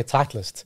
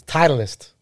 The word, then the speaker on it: Titleist: So we in South Africa say Titleist. – Yeah. – But if you hear the Americans say it on TV or inside, it's Titleist.